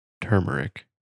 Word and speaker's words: turmeric: It is turmeric. – You're just reading it off the screen, though. – No, it's got the phonetic spelling. Turmeric.